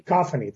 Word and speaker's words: Cacophony: Cacophony.